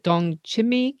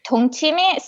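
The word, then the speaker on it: 동치미: The is it dong Chimmy Kimmy? – dongchimi. So dongchimi.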